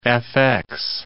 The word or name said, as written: FX